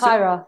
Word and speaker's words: Kairos: Is it Kairos? Is that how you pronounce Kairos.